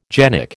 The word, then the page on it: genic: American English